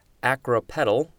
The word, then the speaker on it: acropetal: As in acropedal development or of floral buds. Acropedally is the adverb. And I think I pronounced it wrong. I think it's acropetal, and petal is like a petal of a flower, which is why you've got that example of floral buds.